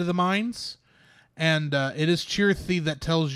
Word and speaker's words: Chirithy: And also, there we do find out that the Grey Cat's name actually is Chithery. I think I said it wrong earlier. It's Chirithy. Sorry, it is Chirithy.